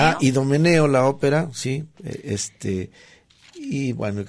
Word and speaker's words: Idomeneo: Ahí se pronuncia, está, bueno, mal pronunciado, es Idomeneo, rey de Creta, y el rapto del Serrallo.